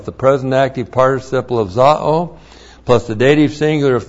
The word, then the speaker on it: zao: Z-A long O, zao.